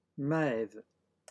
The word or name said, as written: maeve